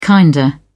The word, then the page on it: kinda: British English